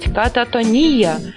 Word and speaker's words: кататония: Ехех пишет, что правильное ударение должно быть кататония. Кататония. Нет, подождите, надо как-то собраться с мыслями. Так, давай, соберись, тряпка. Да, на последний слог.